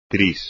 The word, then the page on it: tris: espagnol